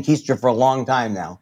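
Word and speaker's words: Koester: It seems to be on a slightly less rigorous schedule than the podcast, which is weekly, but you do it with Megan Kester, if I'm pronouncing her name right. – I think it's Keister. Well, I know it's Keister.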